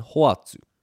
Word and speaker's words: hoatu: We have homai, which means pass me or give me, homai, three syllables, and hoatu. Ho-a-tu, three syllables again.